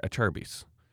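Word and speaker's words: Acerbis: And then I had a few people correcting me, say it like a chair bus, a Cherbus, Cherbus. And I was like, okay. So I've, I, I Googled it and it's an Italian word and it's spelt with a C, but it said Acharbis.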